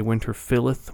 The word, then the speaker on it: Winterfylleth: The band is called Winterfylleth, and I heard that pronounced, because I've heard people say Winterfylleth, Winterfylleth, either one, but I heard it pronounced that way by the band themselves when I saw them in Dublin, Ireland back in 2013, and they played the Redemption Fest, which is that festival that's thrown by Primordial.